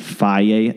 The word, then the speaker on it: Fage: I usually eat Faye, F-A-G-E. That's how you pronounce it, Faye.